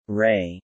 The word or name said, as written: Ray